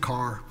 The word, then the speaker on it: Ker: Even though my name is spelled K-E-R, we do pronounce it Carr.